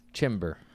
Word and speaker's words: Chimbor: It was Chimber, but yeah. – Chimber. – The O can be an E. It can be an O.